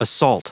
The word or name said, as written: assault